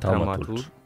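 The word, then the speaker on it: Thaumaturge: Tutaj nie pamiętam, jak to się wymawia. – Traumaturcz.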